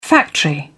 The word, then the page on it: factory: British English